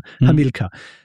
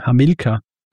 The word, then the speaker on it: Hamilcar: Meistens bei diesem Konsens ankommen, dass bei Hamilcar wird es eben so basierend auf diesen lateinischen Regeln ausgesprochen. Hamilcar. Aber wie gesagt,